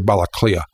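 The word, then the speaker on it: Baile Átha Cliath: For example, the name for Dublin in Irish is three long words, which I won't spell out, but it's pronounced simply Balaclia.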